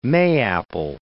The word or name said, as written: mayapple